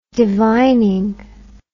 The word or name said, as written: divining